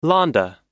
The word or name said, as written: Lahnda